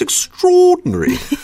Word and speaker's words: extraordinary: Now, this one, again, does remind me of my British friends, and they would really stress that middle part, too. Like, oh, that's extraordinary.